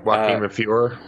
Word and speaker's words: Joaquin de Fiori: Did I say that right? – Correct. Yeah. Joaquin de Fiori. Yep.